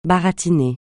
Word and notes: baratiner: francais

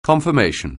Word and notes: confirmation: anglais

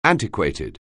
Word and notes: antiquated: anglais

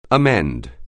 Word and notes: amend: anglais

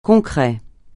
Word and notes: concret: francais

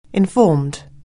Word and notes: informed: anglais

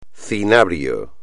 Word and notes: cinabrio: espagnol

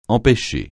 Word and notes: empêcher: francais

